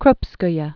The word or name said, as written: Nadezhda Konstantinovna Krupskaya